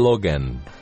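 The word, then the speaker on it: Logan: همون لوگان خودمون فارسی میگیم لوگان بابک چون خیلی خارجی میگیم لوگن اینجوری تلفظ خارجیش لوگن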